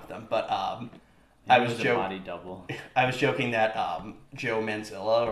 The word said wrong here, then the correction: Mazzello: Mazzello, right? – Mazzello, whatever.